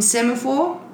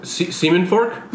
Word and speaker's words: Semaphore: Semen Fork? – Not quite. It's a pronunciation thing, yeah. You got that. – Isn't that what you said? – Really wrong. Semaphore.